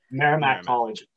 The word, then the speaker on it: Merrimack College: Merrimack College.